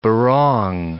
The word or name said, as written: barong